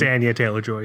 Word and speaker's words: Anya Taylor-Joy: It's Anya Taylor-Joy, yeah.